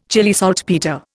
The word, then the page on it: Chile saltpetre: Indian